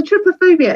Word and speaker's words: trypophobia: I believe it's pronounced trypophobia, but I'll stand corrected. Chrissy Giles, tell us about this syndrome. And first of all, is that how you say it? Trypophobia? – That's how I say it.